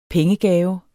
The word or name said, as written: pengegave